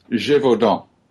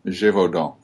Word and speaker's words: Gévaudan: Well, how should it best be pronounced? – Gévaudan. Gévaudan. You wouldn't actually pronounce the N. – The N at the end.